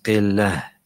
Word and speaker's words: قِلَّة: There's a ha there. It's not a ha. It's actually a ta al-mabulta. You pronounce it قِلَّة.